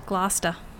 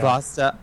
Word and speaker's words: Gloucester: You have to learn to pronounce it though. – Gloucester. – There you go. – Gloucester.